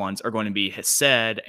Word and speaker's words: Hesed: I don't make the like guttural sounds. So I would also pronounce it Hesed and Shalom.